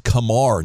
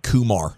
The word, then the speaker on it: Kumar: It's pronounced Kumar, not Kumar, like the movie, just in case you were wondering.